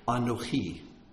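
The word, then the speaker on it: Anohi (אנכי): Anohi is how it's pronounced.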